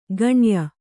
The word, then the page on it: ಗಣ್ಯ: gaṇya